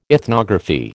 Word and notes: ethnography: American English

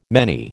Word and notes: many: American English

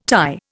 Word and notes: Thai: Indian

